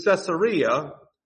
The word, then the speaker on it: Caesarea: So Paul has to go from Jerusalem to Caesarea. I say Caesarea. Do you know how the word is spelled? If you see it in your Bible, it's Caesar and then EA. They named it after Julius Caesar.